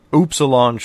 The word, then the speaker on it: Upsilon: Got the Upsilon Shuttle, as it's properly pronounced, you know, I've been told.